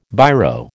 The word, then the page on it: Biro: American English